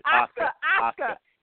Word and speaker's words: Asuka: Oh, yeah, and especially speaking of paying homage, you got Charlotte, the big win over Asuka? – Right, that's it. Asuka.